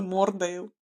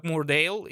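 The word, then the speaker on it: Moordale: Почему Мур? Я думала, это Мордейл. – Ну, потому что там двойная «оу», и это читается как «Мурдейл», и в русском дубляже она переводится как «Мурдейл». – Блин, не знаю, я на английском смотрела, они говорят «Мурдейл» с «бритиш».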